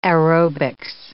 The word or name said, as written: aerobics